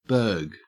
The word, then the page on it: burg: Australian English